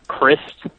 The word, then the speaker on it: Christ: Scott Crist, which is spelled like Christ, but is in fact pronounced Crist,